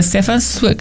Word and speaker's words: Stefan Zweig: Entonces, en base a esas hipótesis, donde el que más se aproxima es un libro, Magallanes, El hombre y su gesta, de Stefan Zweig. Se dice así, ¿verdad? – Sí, como que es como, bueno, sí, en base a lo que nos hemos enterado, como los chismes de barrio.